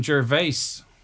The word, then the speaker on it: Gervais: You don't pronounce the S in Gervais.